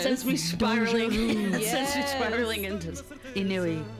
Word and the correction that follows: ennui: Ennui. It's spelled Inui for everyone.